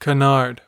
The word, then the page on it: canard: American English